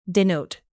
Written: denote

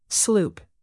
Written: sloop

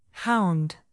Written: hound

